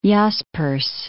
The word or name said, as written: Jaspers